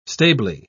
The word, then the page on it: stably: British English